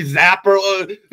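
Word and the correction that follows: Zappe: Bailey Zappi.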